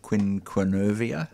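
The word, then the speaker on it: quinquenervia: So it's Melaleuca quinquevera. So quinquennervia. – Oh, quinquennervia. I always drop a few syllables.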